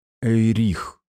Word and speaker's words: Эйрих: Некоторые твои соплеменники некогда называли меня Эйрих». Услышав имя с ударением на второй слог, между прочим, я почувствовал слабину в коленях.